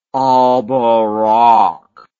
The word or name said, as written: alborak